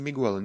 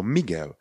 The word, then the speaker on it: Miguel: Jak se píše? No píše se Miguel, ale pozor, není to žádný Miguel, nevyslovujeme jako Miguel, je to Miguel.